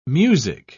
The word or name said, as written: music